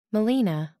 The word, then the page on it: melaena: American English